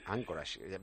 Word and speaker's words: Anchorage: Anchorage, ¿ves? Ya sabemos pronunciarlo. Yo decía Anchorage como si supiera yo inglés y no tenía ni puñetera idea. Grace Anderson, muchísimas gracias.